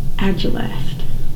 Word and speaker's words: agelast: And the word for this week is agilest. Yes, agilest. It is a noun. It is spelled A-G-E-L-A-S-T.